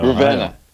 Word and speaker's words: Ravenna: Ravino. – Ravenna. Ravenna. – Ravenna. – it's Ravenna.